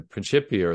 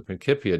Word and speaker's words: Principia: That people like Newton, in writing the Principia or the Principia, depending on your preferred Latin pronunciation,